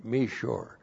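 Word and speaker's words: Mishor: M long I S H long O R. Meshor.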